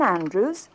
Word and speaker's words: Andrews: Helen Andrews, 33 Bedford Road. – Helen Andrews. Would you please spell that? – A-N-D-R-E-W-S.